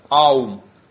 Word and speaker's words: Аум: Он говорит, это слог «Ом», он более полно произносится как «Аум».